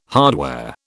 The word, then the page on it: hardware: British English